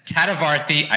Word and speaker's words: Tadavarthy: I have a very, very, very special guest with me today, Anita Tadavarthy.